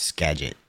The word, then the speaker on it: Skagit: The G is pronounced as a G, so it's Skagit.